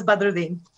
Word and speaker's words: Badruddin: The immigrant pronunciation, yes. Otherwise, it's Sherose Badruddin.